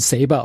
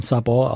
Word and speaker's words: Sabor: Ich habe gelernt, dass man das nicht, also im Englischen, die Amerikaner sprechen Saber aus, aber waren viele spanischsprachige Crewmitglieder auch an Bord und die sagen, also eigentlich spricht man Sabor aus.